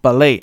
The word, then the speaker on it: ballet: 如果这个 b a l l 是我们熟悉的那个球的话，它还可以再延伸出来一些东西，比如说 ballet，就是我们讲的芭蕾啊，b a l l e t ballet，哎，那个 t 没有发音哈，ballet 就是芭蕾舞嘛，我们是音译过来的 ballet。